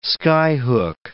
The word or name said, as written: skyhook